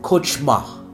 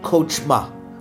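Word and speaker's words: kochma (חכמה): Wisdom, Hebrew word, C-H-O-C-H-M-A-H. It'll pop up on your screen. You pronounce it, kochma. Kochma. Wisdom, Hebrew word, kochma.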